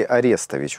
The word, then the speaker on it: Арестович: Есть такой персонаж, уже практически мифологический, по фамилии Арестович. Вот так у него ударение делается.